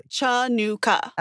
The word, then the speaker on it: Chanukah: By the way, Chanukah is how you would phonetically read it. – Absolutely. – Chanukah.